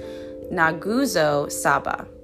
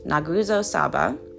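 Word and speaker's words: Nguzo Saba: The principles, called the Guzo Saba, Nguzo? Hold on, I did look up the pronunciation. It's pronounced Naguzo Saba. Naguzo Saba.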